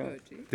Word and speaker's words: Hertie: Hertie.